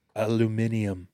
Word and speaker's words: aluminium: Can you say it like a British person? – Aluminium. – It's aluminium.